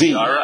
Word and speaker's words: Ziara: Is it Ziara? – Yeah, it's pronounced Ziara, I guess. – Z, like Z or I, is it long or short? – Ziara, gotcha.